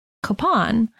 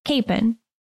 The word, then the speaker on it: Kapaun: It's spelled K-A-P-A-U-N, and there are many who pronounce it Capon, and that seems to be a perfectly acceptable pronunciation. But it seems that the people from closer to his hometown, Pilsen, Kansas, pronounce it Capon. So that's the pronunciation that we've opted to go with.